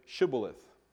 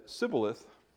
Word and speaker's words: Shibboleth: If he replied no, they said, "All right, say Shibboleth." If he said Sibboleth, because he could not pronounce the word correctly, they seized him and killed him at the fords of the Jordan.